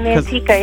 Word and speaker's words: Manteca: So, I mean, growing in a small town, you're from North County, Manteca, right? Is that how you pronounce it? – Yeah, I'm from Manteca, yeah.